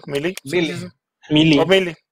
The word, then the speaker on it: Melee: Melee. – ¿Sí? ¿Se oh, pronuncia sí. – así? Sí, sí, Meli Meli.